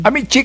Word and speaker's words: amici: And kung nanood ka noong Amici, ang sabi ni Justice Panganiban, disillable, distress in the word is in the first syllable. It's Amici.